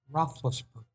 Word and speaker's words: Roethlisberger: How do you say his last name again? – Roethlisberger.